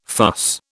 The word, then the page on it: fuss: British English